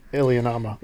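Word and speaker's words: Iliamna: Like the Miliana monster in, did I say that right? – Lake Ilianama.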